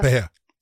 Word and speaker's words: Pereira: Is it Pereira? – Pereira. – Okay, I want to make sure because everyone keeps giving me a hard time with the way I pronounce it. Pereira.